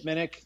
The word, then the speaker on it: Minnich: I can be found on the internet on Twitter at Chip Minnick. Last name is spelled M-I-N-N-I-C-H.